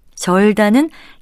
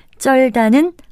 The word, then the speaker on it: 절다: 세 번째 표현으로 나온 땀에 쩔다가 잘못된 표현인데요. 이것은 절다로 고쳐야 합니다. 절다는 땀이나 기름 따위의 더러운 물질이 묻거나 끼어 찌들다라는 뜻인데 부동 된소리로 발음하는 쩔다로 많이 익숙해져 있지만 쩔다는 없는 표현입니다.